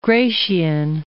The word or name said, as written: Gratian